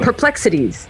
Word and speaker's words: Perplex Cities: I'm like, because it's the word perplexity, but I made it into cities, so you have to stop, and that's awkward. Anyway. – Perplexities.